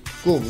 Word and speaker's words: Cum: Kum, ¿no? C-U-M, Kum, ¿no? – Así que sí. Kum, ¿no es cierto? – Sí, claro.